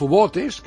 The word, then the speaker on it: U-botes: Cuando Alemania decide hacer la guerra total, que ya lo había hecho en la Primera Guerra con los submarinos, con los U-botes, que sería la pronunciación española, ellos se lanzan al Atlántico para evitar que llegaran los suministros a Inglaterra.